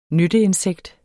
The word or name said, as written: nytteinsekt